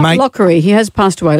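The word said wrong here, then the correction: Locorriere: Let's say his name correctly, she said, looking for the piece of paper. Lockerriere.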